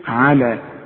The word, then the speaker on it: على: نزلنا على العين، العين دائما مرققة في القرآن، على مرسومة ايه لكن عليها ألف صغيرة، ها؟ عند لما تسمعها وأقول لك اكتبها على حسب ما بتسمعها، تكتب على اللام وبعدين ألف، تنطق كده لكن تكتب بالياء. شوف على والألف الصغيرة دي عشان تدي لك ألف كاملة، على عبدنا.